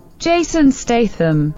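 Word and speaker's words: Statham: Джейсон Стейтем.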